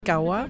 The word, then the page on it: Kawa: arabe